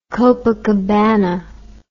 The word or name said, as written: copacabana